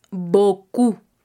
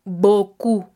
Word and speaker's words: beaucoup: Se escribe J-E espacio T apóstrofo A-I-M-E A-I-M-E y el adverbio boku B-E-A-U-C-O-U-P boku. Entonces, no exageremos el sonido de la O-U-U haciéndolo como una U,